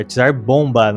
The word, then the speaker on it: Tsar Bomba: É Tsar Bomba, na verdade. Alguém me corrigiu quando eu falei errado lá no, no outro podcast. Que burro! É pra, errado de propósito, é bomba mesmo.